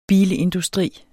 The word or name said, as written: bilindustri